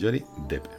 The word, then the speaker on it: Johnny Depp: Sí, se pronuncia Johnny Depp.